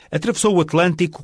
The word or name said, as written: atravessou o atlantico